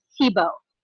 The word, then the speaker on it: Thibeault: And then my last name is Thibeault. So it's French.